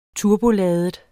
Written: turboladet

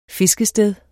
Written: fiskested

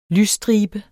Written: lysstribe

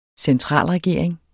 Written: centralregering

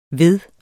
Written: veddet

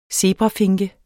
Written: zebrafinke

